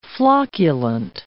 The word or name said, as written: flocculent